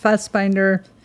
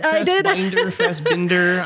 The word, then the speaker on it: Fassbinder: I've seen a couple of his films, but it's been a while with Fassbinder. That's how you pronounce it. But Fassbinder.